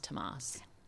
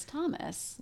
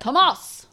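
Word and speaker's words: Tomás: How do you know that it's pronounced Tomas? – Because there's a little thing over the A. – An accent aigu. – Uh-huh. Okay. Is that right, do you think? Or do you think it's Thomas? – No, I like the way you said it.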